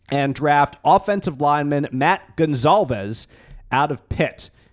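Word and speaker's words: Goncalves: And if you're looking for a Z in his last name, you will not find it, but it's still pronounced Gonsalves. – I'll call him Matt because I'll mess it up. – Just call him Matt. The C is pronounced like a Z.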